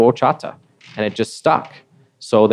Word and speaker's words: horchata: Well, you know, Lucky O, when I first heard of horchata, I wasn't really pronouncing it right. I was calling it Ochata. – Okay. – And for the first three or 4 months, I was calling it Ochata, and I continually got corrected by people that knew how to pronounce it correctly.